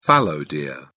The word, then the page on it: fallow deer: British English